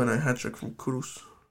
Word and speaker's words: Kudus: Kudus? How do you pronounce it? – Mohamed Kudus.